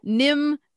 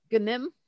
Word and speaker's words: Gnim: But Nim is spelled with a G. – All right. – It's spelled G-N-I-M. That sounds pretty cool. – Yeah, but the G won't be silent. – Gnim? – Gnim.